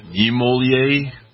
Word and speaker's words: Niemöller: Niemöller.